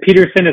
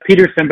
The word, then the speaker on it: Pedersen: So, you know, I go by Michael professionally, but I created that LinkedIn a long time ago, but and Peterson is obviously P-E-D-E-R-S-E-N, so it's spelled a little different, Mike Peterson, but I'm all over LinkedIn, so connect with me on LinkedIn as well.